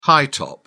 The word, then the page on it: high-top: British English